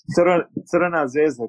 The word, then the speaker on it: Crvena Zvezda: Crvena Zvezda.